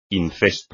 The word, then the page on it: incesto: espagnol